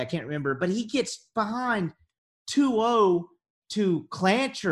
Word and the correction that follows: Clonch: or Clonch. I can't remember how to pronounce that kid's name. – Clonch, yeah.